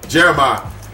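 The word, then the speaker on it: Jeremih: How do you say his name? Jeremiah? – Yeah. – Jeremiah.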